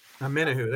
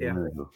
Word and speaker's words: Omenihu: And I deleted it, but Omen Nihu. I don't know. – Spell it for me. – Omenihu. – Omenihu. – Omenihu. There you go. You guys got to get Omenihu.